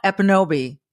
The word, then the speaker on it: Ekpunobi: Amla Ekpunobi, don't pronounce the K, folks. You'll see it there, but it's silent, you know, which is kind of neat.